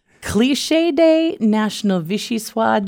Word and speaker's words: vichyssoise: Also, National Vichyosi Day. I'm not French, so I don't know how to pronounce that, but it's a thick soup made of boiled and pureed leeks. – Are you talking about vichyssoise? – Yes, of course. Vichyssoise. Maybe I should have had you say that one. – Okay. Well, I think that's how it's pronounced.